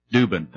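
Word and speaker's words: Dueben: We have the love given to us from Sister Linda Norwald, Brother David and Sister Kathy Harp, Sister Camille Dubin, D-U-E-B-E-N, I'm not sure if I'm pronouncing the name correctly. Dubin, thank you.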